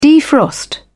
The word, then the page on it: defrost: British English